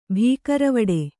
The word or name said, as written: ಭೀಕರವಡೆ